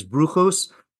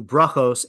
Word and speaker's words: brachos: So for example, you just refer to them as bruchos as opposed to brachos, and then you said tamar instead of tamar.